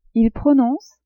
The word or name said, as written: il prononce